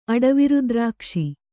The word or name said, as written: ಅಡವಿರುದ್ರಾಕ್ಷಿ